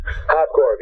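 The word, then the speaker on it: Hoppkorv: I mean, my favorite albums from Hot Tuna is American Choice, Yellow Fever, and is it Hopkorv? Is that how you pronounce it? – Hopkorv, yeah.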